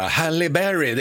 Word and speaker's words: Halle Berry: Halle Berry.